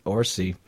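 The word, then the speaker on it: Orci: Orsi, along with CSI New York executive producer Peter Lenkoff.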